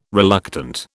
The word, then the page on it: reluctant: British English